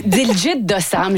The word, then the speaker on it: Diljit Dosanjh: Et on va commencer cette fois-ci avec le chanteur indien Diljit Dosanjh. – Oui, ben c'est comme ça que je le prononce aussi. Diljit Dosanjh, je ne sais pas si vous l'avez croisé ou entendu.